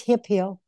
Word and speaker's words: Hemphill: Do you pronounce your last name Hemphill or Hemphill? – A lot of people pronounce it that way, but that's fine. No, it's Hemphill.